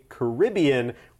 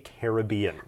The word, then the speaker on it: Caribbean: Now, one other thing I'd love to bring up is the fact that I would often say Caribbean, whereas I heard you say Caribbean. – I did, and there are different pronunciations of that place, and both are correct.